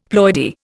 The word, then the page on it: ploidy: Indian